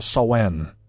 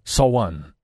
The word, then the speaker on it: Samhain: Well, Samhain is how it's written, but Samhain is apparently how it's pronounced.